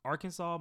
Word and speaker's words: Arkansas: Arkansas must be pronounced Arkansas. And that's spelled A-R-K-A-N-S-A-W. Arkansas.